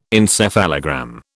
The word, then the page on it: encephalogram: British English